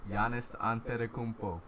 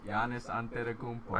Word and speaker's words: Giannis Antetokounmpo: Giannis Antetokounmpo. – One more time. – Giannis Antetokounmpo. – Can you say it three times fast? – Giannis Antetokounmpo.